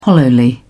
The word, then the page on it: hollowly: British English